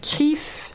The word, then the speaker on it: Crif: Euh, Ensuite, 1er juin, il y a Foreign Diplomats, Show Sauvage, Crif. Ça se prononce comme ça, Crif.